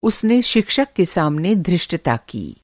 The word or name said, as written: धृष्टता